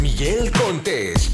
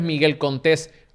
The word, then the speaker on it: Miguel Contés: Y con ustedes, Miguel Contés, con acento en la E. Bienvenido a otro episodio de Cereal Empresarial. Mi nombre es Miguel Contés con acento en la E y este es el podcast que nutre tu mente con ideas innovadoras.